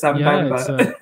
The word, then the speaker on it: Sambamba: So it's Sambamba.